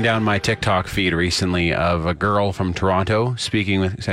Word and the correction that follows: Toronto: I just said it wrong, didn't I? – Toronto speaking with the Toronto accent.